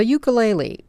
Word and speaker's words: ukulele: The ukulele, or more properly pronounced, ukulele, originated in Hawaii in the 19th century.